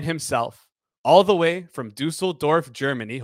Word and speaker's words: Düsseldorf: So first of all, you pronounce Düsseldorf uh, much better than Rob after probably like 30 tries.